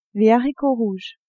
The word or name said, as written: haricots rouges